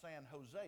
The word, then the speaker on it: San Jose: He said, that's pronounced San Jose. He said, the J's sound like H's.